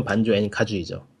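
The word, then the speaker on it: Banjo-Kazooie: 슈퍼마리오 64가 포문을 열었다면 레어가 그거를 반조앤 카조이에서 완성했다고 말해도 될 정도인데 무슨 게임이야? 반조 반조앤 카조이 이게 발음이 맞나 모르겠다. 반조와 카조이. 음. 그러니까 영어라면 반주 앤가주이죠 음.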